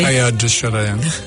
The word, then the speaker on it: Teilhard de Chardin: It's pronounced a bit differently. – Teilhard de Chardin.